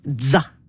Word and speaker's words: ZZA: On l'appelle DZA. Comme la fin de pizza.